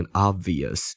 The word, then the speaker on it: obvious: B 基本上在嗯在在念的时候是不会把它念的那么清楚，基本上是不发音的啦，要不然这个字会变得非常的难念。就是口语基本上就只念 obvious 就好了。